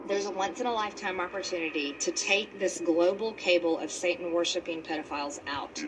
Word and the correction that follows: cabal: It's pronounced cabal.